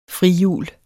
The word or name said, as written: frihjul